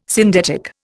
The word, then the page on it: syndetic: Indian